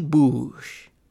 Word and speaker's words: Boosh: Boosh.